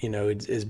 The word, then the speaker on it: Ai: And my interpretation, is based on this right here. When all the kings heard about Jericho and Ai, or I, as I hear some people pronounce it, that's when the idea was formed.